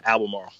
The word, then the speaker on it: Albemarle: But are you you live in Albemarle, right? – Yeah, Albemarle. – Albemarle. Sorry, when I was talking to Jared Jackson, I think I said it wrong too, and he corrected me, and I'll say it wrong again.